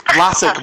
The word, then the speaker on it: Blasick: Blasick.